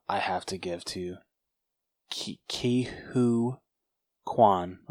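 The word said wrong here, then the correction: Ke Huy Quan: I'm sorry, I'm butchering this man's name. Who played Wayman. – Ke-Hoi. – Ke-Hoi, thank you.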